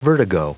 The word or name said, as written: vertigo